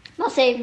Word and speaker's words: Marseille: Marseille wird es ausgesprochen, oder? – Marseille, Marseille, Marseille.